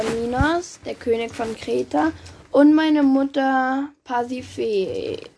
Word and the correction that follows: Pasiphae: Äh, nein, wie spricht man das aus? – Pasiphae.